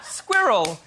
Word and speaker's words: squirrel: The correct pronunciation for squirrel is squirrel. It is. Let me say this. – It's two syllables.